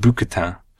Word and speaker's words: bouquetin: And they've sent us a postcard with 2 goats on the front, alpine mountain goats, which are called bouquetin. – Spectacular pronunciation. – Thank you. – Very impressive.